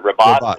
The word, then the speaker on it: Rabat: Did I pronounce Rabat correctly? Is it Rabat? What is it? – Rabat. – It's Rabat. Okay.